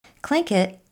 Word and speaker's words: Tlingit: Tlingit sounds like K-L-I-N-K-I-T, but it's spelled T-L-I-N-G-I-T.